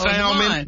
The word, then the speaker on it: salmon: That's Salmon. That's Salmon. – Salmon has that, well, that L is supposed to be silent, ain't it? – Kind of, sort of.